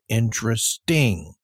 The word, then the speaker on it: interesting: So, of course, in English, it would be I-N-T-E-R-E-S-T for interest. And, of course, you would just add an I-N-G to make it interesting. Interesting. So this looks like it would be pronounced interest, interest, or interesting, interesting. And there are actually a few people that say it, but it is considered incorrect.